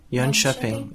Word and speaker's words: Jönköping: But you need to help me pronounce it again. Uh, Yun Shopping. Jönköping. That's yes, right. That's perfect.